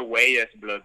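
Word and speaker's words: Weyes Blood: Pues, Wise Blood, yo siempre lo pronuncio Wise Blood porque W-E-Y-E-S.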